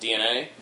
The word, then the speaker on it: DeNA: It's spelled D-E-N-A, but it's pronounced DNA.